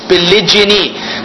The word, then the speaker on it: polygyny: So there's a difference in pronunciation and a difference in spelling. Pronunciation wise, there's polygamy with a g versus polygyny with a j, with a g. And then the spelling, as we say, g-a-m-y, polygamy, g-y-n-y, polygyny. Polygyny.